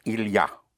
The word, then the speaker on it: il y a: An expression that is used a lot in French is il y a. Il y a. Spelled I-L-Y and A. Il-I-A, but pronounced il-ya.